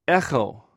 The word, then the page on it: ecw: greek